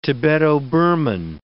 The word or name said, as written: Tibeto-Burman